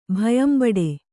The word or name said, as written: ಭಯಂಬಡೆ